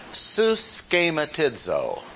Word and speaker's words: suschematizo: Let me pronounce the word that is translated conformed for you and see if you can get its English derivative. Suschematizo. And you can kind of go lightly on the su. Schematizo.